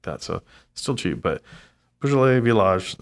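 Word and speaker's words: Beaujolais Villages: That's how it's spelled on when you guys look at it. It's going to say Villages, pronounced Beaujolais Village.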